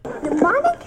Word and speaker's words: mnemonic: Mnemonic has a silent M?